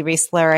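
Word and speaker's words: Reisler: Instagram, YouTube, if you look up just Julie Riesler, I have a ton of my podcasts and videos on Instagram, Facebook, LinkedIn.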